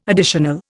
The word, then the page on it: additional: Indian